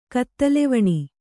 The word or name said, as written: ಕತ್ತಲೆವಣಿ